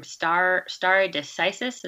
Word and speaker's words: stare decisis: And you pronounce that term stare decisis, is that right? – That's correct, yes.